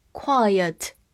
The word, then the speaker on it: quiet: quite 表示相当，它末尾是 te，所以末尾的 e 不发音；而 quiet 把 e 放到了 t 前面，它是要发 a 这个音的，所以读作 quiet，quiet。表示安静的，大家可以记一个短语：quite quiet，相当安静。